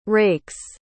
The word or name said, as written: rakes